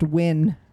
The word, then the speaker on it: Wynn: It's pronounced Wynn. Like Andy Wynn, the vegetarian.